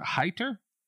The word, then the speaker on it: Heiter: So it's the It Follows entity versus Dr. Heiter.